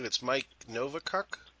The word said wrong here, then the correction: Novakovic: I keep mispronouncing his last name. Can you help me on that one? – Well, Novakovic. – Novakovic.